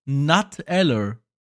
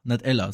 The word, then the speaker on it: Nutella: Aber eigentlich heißt dieses Produkt, und das könnte man sich eigentlich auch herleiten, es heißt Nuteller. Weil, oder Nutella, Nutella, so. Weil, ganz einfache Erklärung, das Nut kommt selbstverständlich vom Nuss so, und das wird englisch ausgesprochen.